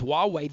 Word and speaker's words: Huawei: It's H-U-A-W-E-Y. It's pronounced Huawei.